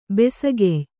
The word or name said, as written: ಬಿಸುಗಯ್ಯ